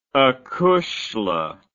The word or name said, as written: acushla